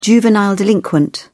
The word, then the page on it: juvenile delinquent: British English